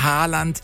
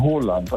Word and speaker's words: Haaland: Erling Haaland ist falsch, ne? – Ja, das ist absolut falsch. Er wird ausgesprochen Haaland, also mit O, ja.